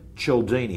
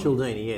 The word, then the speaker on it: Cialdini: The author we're going to talk about is Robert Cialdini. Have I said it right? – Cialdini, yeah. So it's Italian name, I believe.